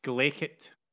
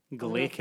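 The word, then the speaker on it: glaikit: Well, the word I have chosen for you to add to your ever-expanding vocabulary today is the word glickit. – First of all, we really like it at the end of the... Yes. – Glickit? Like G... – Glickit. – Glickit.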